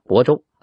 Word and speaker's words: 亳州: 然后紧接着部署，命黄德功、左良玉进兵挺进颍州，奔向亳州。啊，那个亳就是呃毫米的毫少一横啊，很多人都读成亳州。亳州那是错别字啊。亳州，安徽亳州。